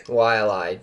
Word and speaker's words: Wai'alae: Yeah, those picks we tweeted from the DNVR golf account today of Wai'alae, how do you pronounce it correctly? – Wai'alae, dude, that course fucked me up.